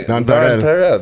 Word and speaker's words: Nonpareil: You're French and you don't even say it right. Like, huh? Yeah, Nonpareil. – That's what it's supposed to be.